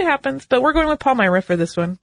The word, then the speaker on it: Palmyra: I know they say Palmyra in the original episode. I listened to a bunch of different pronunciation guides. The overwhelming majority went with Palmyra.